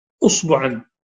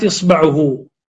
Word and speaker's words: إصبع: قال إذا قطع جناية عمدا أصبعا، الأصبع الفتحة الألف هنا والهمزة هنا تنطق لها تسعة ألفاظ، والكلمة أصبع، أصبع وإصبع وإصبع، وبثلاث حركات أو عشر، بتسع لغات، تسع أو تسعة ألفاظ بتغيير الحركات فيها. قطعه عمدا فعفى من قطعت إصبعه، فعفى المجني عليه عنها، يعني عن الجناية، عن الجناية، عن القواد في الجناية.